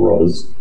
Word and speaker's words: Roz: Not R-O-S-E, but R-O-Z. – Oh, yeah. Telephone Rose. – But pronounced Rose. – Great. Maybe people keep getting it wrong and he has to correct them. – It's not Roz, it's Rose.